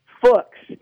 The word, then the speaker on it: Fuchs: And I am going to say his name very slowly to make sure I pronounce it correctly, but Scott Fuchs is actually how it's pronounced. It rhymes with Rooks, the chess piece.